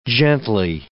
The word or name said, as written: gently